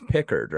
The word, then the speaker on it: Pickard: Are you saying Picard is a bit? It's Pickard, right? – I was kind of mispronouncing it, but I know it's Pickard, but I have been saying Picard because I've been thinking of Picard from Star Trek lately.